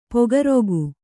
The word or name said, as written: ಪೊಗರುಗು